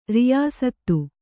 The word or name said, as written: ರಿಯಾಸತ್ತು